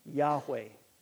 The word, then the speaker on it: Yahweh: So, there truly is no consensus at this time on how it's pronounced, the name of God. But most have landed on Yahweh, Yahweh.